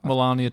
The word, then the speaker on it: Melania: And Melania. And Mike Pence. – Oh, Melania. – Yeah. – Is that a name? – Yeah, I've pronounced it right, haven't I? Melania Trump. – Yeah. Right.